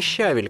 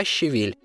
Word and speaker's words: щавель: Именно так, не щавель, как произносят очень многие, а щавель.